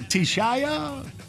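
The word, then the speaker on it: Teshia: Is that you, Tisha? Is it Tishaya? – Tisha.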